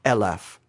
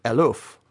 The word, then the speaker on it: eleph: Take the time to get yourself a Hebrew concordance and spend a little time looking at the word eleph. In English letters, spelled E-L-E-P-H most of the time. The original Hebrew word, depending on its pronunciation, means either 1,000 or it means oxen, if it's pronounced eluf.